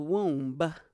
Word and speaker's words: womb: When I was in the womb, she ate. – You just pronounced the B in womb? – Womb.